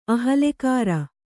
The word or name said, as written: ಅಹಲ್ಲೆಕಾರ